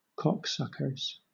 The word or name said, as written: cocksuckers